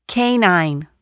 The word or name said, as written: canine